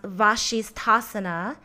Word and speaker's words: Vasisthasana: Now, side plank in Sanskrit is pronounced Vashisthasana, and it allows you to stay calm and focused.